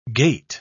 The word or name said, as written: gate